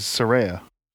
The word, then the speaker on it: Saraya: Saraya, excuse me. The second time I've done that. – I didn't know it was Saraya. – Saraya. She does not like it when people mispronounce her name.